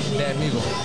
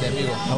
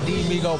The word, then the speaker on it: Demigo: Artist name is Dan Migo. D-E-M-I-G-O, Dan Migo. – Okay. D-E-M-I-G-O, okay. – Yeah, a lot of people like, they pronounce it like Dan Migo or D Migo, but it's Dan Migo.